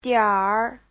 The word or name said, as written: dianr3